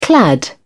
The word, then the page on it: clad: British English